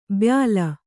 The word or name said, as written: ಬಯಲು